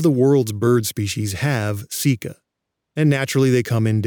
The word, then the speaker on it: caeca: One interesting feature of the large intestine, in some birds, is the presence of structures called ceca. That's spelled C-A-E-C-A.